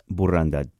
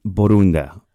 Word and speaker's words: Borunda: Is that right? – Almost. It's Borunda, but that's great. – Borunda. Oh, my goodness. I can't believe I just butchered that.